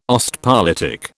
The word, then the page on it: Ostpolitik: British English